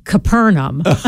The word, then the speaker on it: Capernaum: Because our guide in Israel is Jewish, and so he would say, we are now going to visit Capernaum. Or as you all say, Capernaum. He said, but the way to pronounce it is Capernaum, and you need to use it that way.